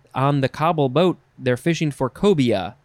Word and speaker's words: COBOL: I think you would just say cobble. C-O-B-O-L.